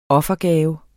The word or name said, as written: offergave